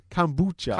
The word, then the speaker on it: kombucha: Am I saying that right? – Yeah. – Kombucha.